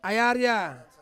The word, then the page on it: ai aria: Ansus